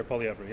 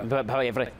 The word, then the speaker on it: Poilievre: What was it Bolivier? – Pierre Poilievre, yeah. – Poilievre, okay, thank you.